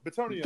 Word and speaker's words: Bitonio: Bitonio.